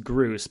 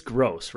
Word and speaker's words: Groose: We both went to Matt Groose, which is confusing to me because it looks like it'd be pronounced Groose, but it is Gross, right?